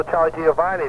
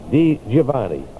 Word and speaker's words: Di Giovanni: No Charlie Giovanni there. You can't say, say it right. Charlie Di Giovanni. No. Charlie Di Giovanni. – Oh, it's D. – Yeah, D-I. Di Giovanni.